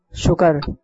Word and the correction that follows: شکر: ہمیں اللہ تعالیٰ کا شکر ادا کرنا چاہیے. اور اس طرح آپ کا جواب ہو گیا غلط. درست تلفظ ہے شکر.